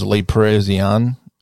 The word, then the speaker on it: Le Parisien: I don't even know how to pronounce this. Le Parisien. Reported. – That sounds good.